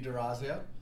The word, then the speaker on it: Dorazio: Everybody on the podcast, the six people listening, this is Dean Dorazio.